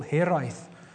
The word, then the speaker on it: hiraeth: And I hope I pronounce it well. It's called hiraeth. Yeah, pretty good.